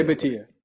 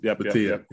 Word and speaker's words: Bhatia: Akshay Bhatia. – Bhatia.